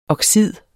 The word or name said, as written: oxid